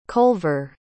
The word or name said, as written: colver